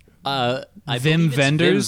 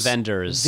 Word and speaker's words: Wim Wenders: But what you mostly got, in my opinion, was chunks of bad Terrence Malick meets did anyone watch that Wim Wenders movie, Wim Wenders, how do you say it? – Vim Wenders. – Vim Wenders.